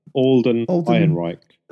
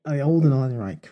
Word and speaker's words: Alden Ehrenreich: Alden Ehrenreich. – Alden Ehrenreich, right, yeah.